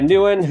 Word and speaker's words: Nguyen: So you would pronounce it as if you spelled it N-E-W-I-N. Nguyen. – Okay, Nguyen. – Nguyen.